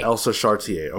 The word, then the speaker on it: Elsa Charretier: Elsa Chartier.